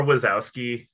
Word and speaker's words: Wachowski: The Wachowski. – Lily. I've always struggled with their last name. – Some people say Wachowski. – Wachowski, file Wachowski paperwork, sisters. Wachowski. – Yeah, or Wazowski is another way.